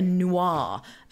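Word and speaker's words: Nuar: I'm going to say, Corey, you recognize in the corner is Tyver, who is a noir. And noirs are basically minotaurs. I don't know if I'm saying, I think that's how you say it, noir. N-U-A-R. N-U-A-R. Noir.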